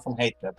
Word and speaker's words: van Heteren: In Dutch, it's Dan van Heten, but close enough.